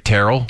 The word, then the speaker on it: Teryl: Is that how you say it? I don't know. – No, I think that you're saying it right. Terrell. – Terrell. – T-E-R-Y-L.